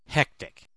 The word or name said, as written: hectic